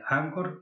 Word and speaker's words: Anchor: Yo lo tengo alojado en, se pronuncia eh, Anchor, se escribe Anchor, anchor.fm.